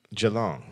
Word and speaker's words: Geelong: Geelong.